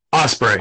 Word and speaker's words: Ospreay: Ospreay.